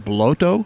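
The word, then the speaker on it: Błoto: Before that, we heard a song called Kozak, K-O-Z-A-K, from a band. I believe the name of the band is pronounced Bloto. It's B-L with a little diagonal line through it, O-T-O, if that helps at all.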